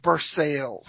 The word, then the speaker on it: Versailles: And we want to know what we have right next to us? Versailles. But everybody says Versailles.